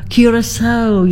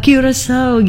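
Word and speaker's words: Curaçao: Oke, kemudian iya tadi ya, oh Mbak Wisa, terima kasih sekali Bu Maharani sudah di sudah diberikan hadiah yang luar biasa, how to say it correctly ya. Jadi curacao gitu ya. Mudah-mudahan betul ya Bu ya. Lidah saya lidah Jawa nih. Oke, curacao gitu ya. Itu cara bacanya Mbak Wiwi, negara di wilayah Karibian.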